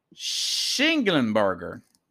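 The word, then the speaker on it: Schnellenberger: Speaking of Lombardi, Carlos, Pickles, Howard Schnagenberger, Am I saying his name right? Former University of Miami Schlingenberger. Howard Schlingenberger. It's a mouthful.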